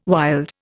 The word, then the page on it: wild: Indian